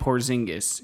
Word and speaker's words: Porzingis: Somebody on Twitter was like, why do you pronounce it Porzingis? – Just Stephen A. Smith YouTube and Porzingis. You'll see. – He pronounced it Porzingis. So if we ever say Porzingis, and I'll probably say this in the next episode earlier on, just that way.